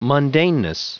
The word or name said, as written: mundaneness